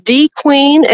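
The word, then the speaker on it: De Queen: So Dequeen, is that the official pronunciation? – It is Dequeen, and it is where the queen has lived for the past 15 years.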